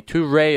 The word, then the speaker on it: Toure: Toure is how you pronounce his first name.